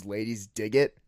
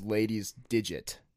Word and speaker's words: Ladies Dig It: But everybody pronounced it, though, as Ladies Digit. So they're like, what does that mean? Like, you have like the Ladies Digit? I'm like, no, like Ladies Dig It.